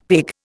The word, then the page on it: pig: Indian